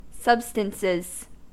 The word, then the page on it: substances: American English